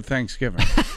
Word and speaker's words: Thanksgiving: So what are you guys doing for Thanksgiving? – Teaching Val where to put the emphasis on which syllable. Thanksgiving.